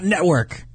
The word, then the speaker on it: network: Network, not network. Network. Pronounce the T in network.